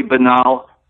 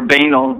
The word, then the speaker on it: banal: I mean, I haven't read his books, but everything he says, I've heard, sounds totally illogical, totally banal, or banal, depending on your pronunciation.